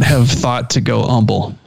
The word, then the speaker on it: Humble: Humble, like without the H, because where Dakota Allen was from.